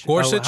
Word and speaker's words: Gorsuch: I don't think they did it to Gershwish. Did they? – Gorsuch? – How do you say his name? – Gorsuch? – Yeah.